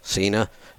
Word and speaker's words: Cena: Yesterday I watched a little video of John Cena. I guess that's how you pronounce the name of the wrestler guy. – Yeah. – Cena.